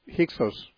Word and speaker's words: Hyksos: that pharaoh may have been one of the Hyksos pharaohs, which is spelled H-Y-K-S-O-S, I believe, Hyksos.